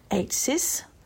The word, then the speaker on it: AIATSIS: It's mainly about the AIATSIS, I guess that's pronounced AITSIS, organisation summit held in the Gold Coast last week.